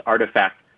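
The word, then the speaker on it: RTFKT: Nike has purchased RTSKT, pronounced Artifact Studios.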